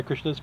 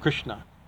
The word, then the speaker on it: Krishna: He says because the it's not Krishna, it's Krishna. You know, the syllables have to be that.